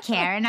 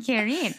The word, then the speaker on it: Kirin: And then all of a sudden it's like a strong, like, I'm not Karen, I'm Kirin. I love the name, though I have been saying it incorrectly. But great for inquiring minds listening who are fellow Kirin lovers. Now we have the correct pronunciation.